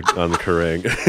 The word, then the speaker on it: Kerrang: There's this other interview that Patrick did with Kerrang. Is that how you pronounce Kerrang.